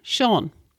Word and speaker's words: Seán: And we spell Seán S-E-A-father, that's a line over the A-N, S-E-A-father-N, Seán.